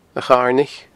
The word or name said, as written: acharnaich